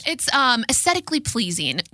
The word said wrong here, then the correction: aesthetically: Aesthetically? – Aesthetically. – Okay. – I might not have pronounced the T the first time.